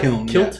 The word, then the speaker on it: kiln: Kiln is the right? – Kiln. Kiln. Okay. – Isn't that like why are you Scottish people? – Look at me like that. – What is it? How do you spell it? – K-I-L-N. – Oh, okay. So like, it's not a kilt.